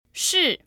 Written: shi4

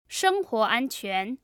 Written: shenghuo anquan

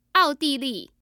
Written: aodili

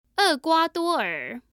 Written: erguaduoer

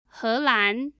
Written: helan

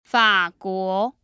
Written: faguo

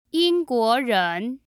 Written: yingguoren

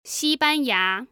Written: xibanya

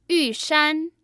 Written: Yushan

